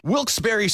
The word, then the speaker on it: Wilkes-Barre: And you're listening to Wilkes Barre Scranton's 97.9X. – Now, I had heard that it was supposed to be pronounced Wilkes-Barre. – Wilkes-Barre Scranton's 97.9X. the only way to pronounce Wilkes-Barre.